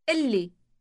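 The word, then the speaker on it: قلي: اللي. اللي. – Note the elongated L sound.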